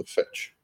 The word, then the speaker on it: fecce: fetch. Now the fetch, as far as I remember, is an Anglo-Saxon term spelled F-E-C-C-E and pronounced fetch.